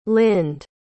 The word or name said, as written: lynde